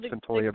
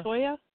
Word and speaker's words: Cyntoya: If you get the chance and you want to check it out, just look up Santoya Brown. – What's her first name? What are you saying? Santoya? It's Santoya? How do you spell that? – C-Y-N-T-O-Y-A. – Santoya Brown.